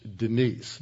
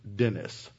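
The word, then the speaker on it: Denis: It's D-E-N-I-S, but it's pronounced Denise, not Dennis.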